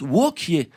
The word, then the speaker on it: Wauquiez: Et Wauquiez, en vrai, ça se prononce Wauquiez.